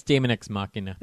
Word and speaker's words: Daemon X Machina: It's Daemon X Machina.